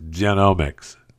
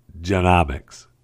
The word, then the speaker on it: genomics: It's pronounced genomics, not genomics.